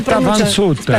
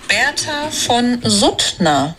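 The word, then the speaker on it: Bertha von Suttner: Berta Van Sutter. – Aspetta, si dice così, senti. – Berta Van Sutter. – Aspetta, aspetta. – L'hai cercato, come si dice? Vediamo. Sentiamo. – Berta von Suttner.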